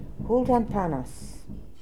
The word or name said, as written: Hooltanpanas